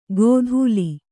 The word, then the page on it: ಗೋಧೂಲಿ: gōdhūli